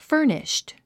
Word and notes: furnished: fə́ː r niʃt; ファニッシュド

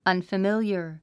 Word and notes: unfamiliar: ʌ̀nfəmíljə r; アンファミリアァ

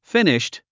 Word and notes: finished: fíniʃt; フィニッシュド

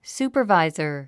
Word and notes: supervisor: súːpə r vàizə r; スーパァバイザァ

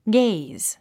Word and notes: gaze: ɡéiz; ゲイズ

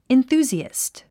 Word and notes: enthusiast: inθúːziæ`st; インスージアスト

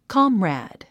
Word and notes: comrade: kάmræd; コムラァド

